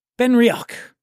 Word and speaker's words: Benriach: And she currently oversees the whiskey-making operations for 3 iconic Scotch brands, Benriach, Glenglassaugh, and Glendronach. Try pronouncing that 3 times fast when you've had a little whiskey. Benriach, Glenglassaugh, and Glendronach. That's how you say it.